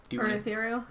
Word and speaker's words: Urithiru: Urithiru.